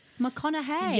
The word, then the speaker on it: McConaughey: I'm saying it right how it... McConaughey.